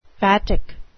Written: vatic

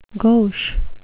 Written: gauche

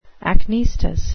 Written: acnestis

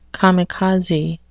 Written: kamikaze

